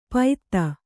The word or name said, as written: ಪೈತ್ತ